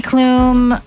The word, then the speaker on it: Klum: Heidi Klum, I believe it's pronounced Klum, is clumbing her way into the 50th level.